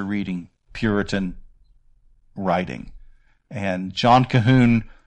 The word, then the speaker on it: Colquhoun: And just because it's pronounced Cahoon, it's not spelled that way. It's C-O-L-Q-U-H-O-U-N. And again, for years, I pronounced it as Colquhoun, and it's actually Cahoon. And John Cahoon has a book called On Repentance, True and False Repentance is the name of the book.